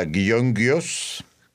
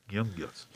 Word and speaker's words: Gyöngyös: porque se pronuncia Gyöngyös. – Gyöngyös.